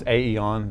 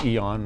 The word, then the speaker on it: aeon: I was calling this Aeon, the Greek word. It's actually Eon. My wife straightened me out on that.